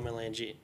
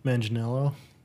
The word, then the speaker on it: Manganiello: He's currently in a party with Joe Mangino. How do you say his name? – Manganiello.